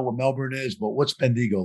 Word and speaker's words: Bendigo: I'll see if I say it right. Bendigo? – Yeah, Bendigo. That's the one.